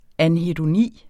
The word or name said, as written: anhedoni